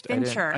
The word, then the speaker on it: Fincher: It's Fincher.